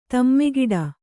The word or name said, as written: ಧಿಮ್ಮಿಕಿಟ